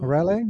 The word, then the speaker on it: Raleigh: Seit einer Woche fahre ich nun mit meinem 2021 äh, Rally oder Raleigh, Ralef? Nein, Raleigh. Wie würdest du Raleigh aussprechen? Hatte ich immer Probleme. – Hatte ich auch immer Probleme. Die gibt es jetzt, oder gibt es ja auch schon ewig. Ich glaube, Raleigh oder so. Raleigh, ja.